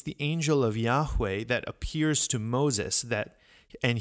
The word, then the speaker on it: Yahweh: But so the traditional and most likely way that this name was pronounced by ancient Israelites is Yahweh, Yahweh.